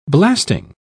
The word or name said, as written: blasting